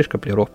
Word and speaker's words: Шкаплеров: по-моему, правильно сказал. Да, Антон Николаевич Каплеров, или Шкаплеров, простите уж за мое невежество.